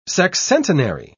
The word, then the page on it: sexcentenary: British English